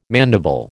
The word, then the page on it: mandible: American English